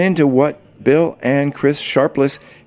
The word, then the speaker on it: Sharpless: Back in August 2009, Barclays Arena wasn't even on the horizon, but I was very interested in speaking with Bill and Chris Sharpless. And by the way, you will notice that I continually and repeatedly mispronounce their last name, calling them Sharples.